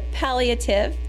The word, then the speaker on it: palliative: And so you pronounce it palliative, and it comes from the word pallium, which means to cloak.